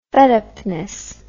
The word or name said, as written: fedupness